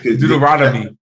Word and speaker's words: Deuteronomy: Deuteronomy.